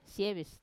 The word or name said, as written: sievis